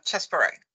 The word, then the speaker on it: Chesbrough: Eh, och fadern till det kanske var Henry, ska se om jag uttalar namnet korrekt här, Chesbrough? – Ja, Chesbrough. Mm.